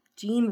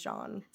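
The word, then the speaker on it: Jean: We have, obviously, Natalie Portman, Gary Oldman giving a very Gary Oldman performance, and then Gene Reno, that can't be how you pronounce his name. It's got to have some accents in there. – I think it's John Reno, maybe.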